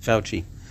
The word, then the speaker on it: Fauci: I don't know if the guy's name is Fauci, is that how it's pronounced? – Mm-hmm. – Fauci.